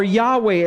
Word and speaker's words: Yahweh: Thus says the Lord of hosts, the name Jehovah or Yahweh as we pronounce it.